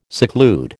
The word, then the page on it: seclude: American English